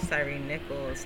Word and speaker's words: Tyre Nichols: Um, I don't want to pronounce his name wrong. Is it Tyre Nichols? Yes, it was Tyre Nichols.